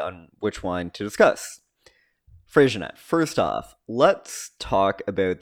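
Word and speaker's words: Freixenet: If you've ever found yourself in the store wondering how to pronounce the wine with an X in the middle of it, it was probably Frisianette, where the X is pronounced as an SH.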